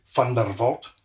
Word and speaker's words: Van Der Walt: The proper way to pronounce it is actually Vandervolt.